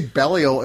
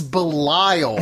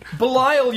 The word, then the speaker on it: Belial: And you say Belial. It's like, it's Belial. – Whatever. – That's how it's pronounced. It's pronounced Belial.